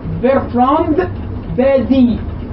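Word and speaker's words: Bertrand Badie: واحد كافر بقى، المستاذ العلوم السياسية بيرترام بادي، هو اسمه بيرترام باديع بس هو فرنسي فما بينطقوش الاسم، اسمه بيرترام يعني بيرتراند، الفرنسيين بينطقوه بيرتراند بادي، بادي باء ألف داليا.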